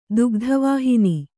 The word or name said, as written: ದುಗ್ಧವಾಹಿನಿ